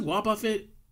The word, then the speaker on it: Wobbuffet: Is it Wobbuffet? Is that how you say it? – Yeah. – I can never pronounce that right. They have one of those things with them. – Wobbuffet. – Wobbuffet? Okay, let's go with that.